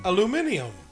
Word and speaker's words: aluminium: Aluminium. – It's aluminium. There are the letters there to facilitate that pronunciation.